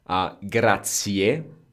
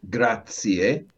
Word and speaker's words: grazie: Grazie, and That's how we pronounce it. Actually, it's one of the most mispronounced words in the world. Grazie. – Grazie. – Very good. You do hear that E at the end, so E at the end, which a lot of people don't say.